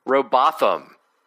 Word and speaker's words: Robotham: That's my name, Robothum. – That is your name. – Robotham. I've been saying it wrong the whole time. It rhymes with Gotham.